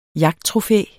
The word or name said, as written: jagttrofæ